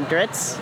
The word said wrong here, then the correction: Drizzt: books? – Drizzt? – Drizzt. Him.